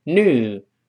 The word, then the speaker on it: new: 连在一块读成 new，它有个 e 这个声音，美音中 u 绝大多数情况下会变成 u 这个声音，就是把前面的 e 去掉，只剩下一个 u 这个声音，所以读成 new，注意不要读怒，不是发怒的怒，不对哦。